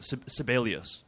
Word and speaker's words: Sibelius: Oh, Gene, maybe I'm not pronouncing it, Sibelius. – Gene Sibelius Park. – Named after the... – You're pronouncing it right.